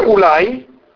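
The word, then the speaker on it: ulai (אולי): Si legge sempre ulai, ma è scritto senza vav.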